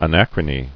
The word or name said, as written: Anachronical